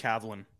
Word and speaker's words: Kavlin: Yeah, he, you know, like Cavallini? – Is it Cavallini? – No, it's just Kavlin.